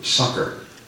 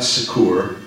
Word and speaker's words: succour: And I don't see Fiona here this morning, but they tell me over in England, that's the way you pronounce that word, succor. Okay, S-U-C-C-O-U-R. It's not succor, it's succor.